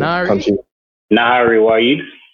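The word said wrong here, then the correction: Nayyirah: Is that how I pronounce your name? – No, that's not how it's pronounced. – I'm so sorry, Miss Wahid. Damn, I feel stinks. – Hold on. – How you spell that? Nahari Wahid. – Yeah, how you pronounce, or pronounce it? – Nira. It's N A Y Y I R A H. – Sorry, Nira Wahid.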